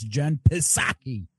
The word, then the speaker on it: Jen Psaki: He said it wrong. That's why it screwed you up. It's Jen Psaki.